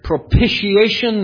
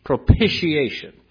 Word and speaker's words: propitiation: And by the way, that is the correct pronunciation. Propitiation. It's as if there's a P-I-S-H in the middle of it.